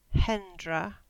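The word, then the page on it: Hendra: Cornish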